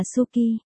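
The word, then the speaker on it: Suki (好き): Thích, phát âm là Suki,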